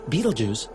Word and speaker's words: Betelgeuse: The massive star Betelgeuse, yes, that's really how you say it, it's a glorious red supergiant at the shoulder of Orion, and it's about to pop.